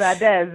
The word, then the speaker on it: Adaeze: You say it like somebody from Imo. – Yes, exactly. – You know, for us, in Anambra, it's Adeze.